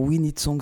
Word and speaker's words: Oui Need Songs: Alors, We Need Songs, ça s'écrit oui comme yes, O-U-I, Need Songs.